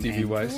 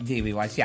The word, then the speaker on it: D.B. Weiss: D.B. Weiss, you got it. – D.B. Weiss, yeah.